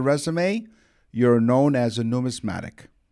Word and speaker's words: numismatic: Numismatic.